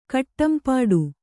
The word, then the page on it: ಕಟ್ಟಂಪಾಡು: kaṭṭampāḍu